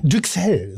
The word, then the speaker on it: Duxelles: Wie Düxel, so heißt es, wie Düxel. Also ich denke, es spricht sich wie Brüssel aus, Brüssel.